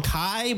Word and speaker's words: Kay: They call him Kai, but it's spelled K-A-Y.